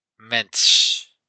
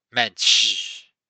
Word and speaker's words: Mensch: It's Mensch. Mensch.